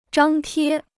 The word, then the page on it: 张贴: zhāng tiē